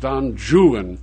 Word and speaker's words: Don Juan: For example, he read Byron's Don Juan, which Byron pronounced Don Juan for scanning purposes.